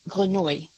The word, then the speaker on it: Grenouille: If Jean-Baptiste Granu, was that a good pronunciation? – Is it G-R-E-N-O-U-I? – U-I-double-L-E. – I think if it's the French word, it's Grenouille.